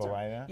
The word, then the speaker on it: Hippolyta: Hippolyta? – Yeah, Hippolyta.